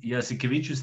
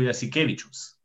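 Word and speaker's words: Jasikevičius: Valančunas, a i nije Jasikevičus, nego Jasikevičus. – Jasikevičus. – Jasikevičus i Valančunas, nemaju taj i koji smo mi navikli.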